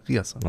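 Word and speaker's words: Ryerson: Richtig ausgesprochen. Riasson.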